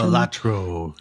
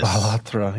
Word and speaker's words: Balatro: Is that how you say it? Question? – Balatro, yes. – Balatro, yeah.